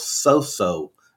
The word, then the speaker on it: Soso: I was actually born and spent my first 18 years in a town called Soso, Mississippi. – And that's spelled S-O-S-O, if I'm not mistaken. – That is correct. – S-O-S-O. Oh, Soso.